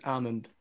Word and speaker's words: almond: We asked an English teacher and she said the L is silent, it is almond.